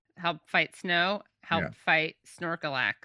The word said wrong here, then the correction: Snorlax: did I get the name right? – Snorlax. – Snorlax.